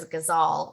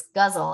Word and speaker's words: ghazal: So I thought it was ghazal, like almost like the animal, but it's guzzle, and it's an Arabic form, and it's absolutely beautiful.